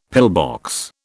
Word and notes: pillbox: British English

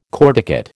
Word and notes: corticate: American English

